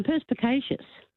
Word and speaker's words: perspicacious: I've got perspicious. – No, perspicacious.